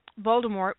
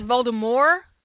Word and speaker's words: Voldemort: One of the reasons why Voldemort, which by the way, did you guys hear that we've been saying it wrong for the past two decades or ever how long it's been out? Apparently, that T is silent.